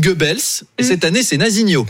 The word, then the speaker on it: Nazinho: C'est vrai que c'est-à-dire que ça se prononce. – Ça se prononce avec l'accent chantant. Nazinho.